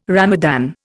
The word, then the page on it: Ramadan: Indian